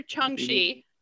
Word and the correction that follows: Shang-Chi: did I say that right this time? – Shang-Chi. – Shang. – I'm never gonna say it right. Shang. – Shang. – Shang. – Shang-Chi.